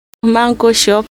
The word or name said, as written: manko